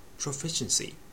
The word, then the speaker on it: proficiency: Proficiency、習熟、堪能。 Profi の Fi の上にアクセント。